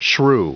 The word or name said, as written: shrew